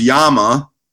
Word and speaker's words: llama: It's pronounced Yama, if you want to be, you know, correct about it and phonetically correct.